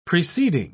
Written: preceding

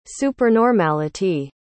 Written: supernormality

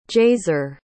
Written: jaser

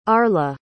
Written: arla

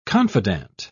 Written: confidante

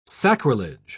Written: sacrilege

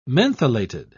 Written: mentholated

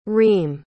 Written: reem

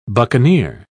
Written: buccaneer

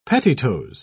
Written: pettitoes

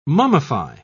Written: mummify

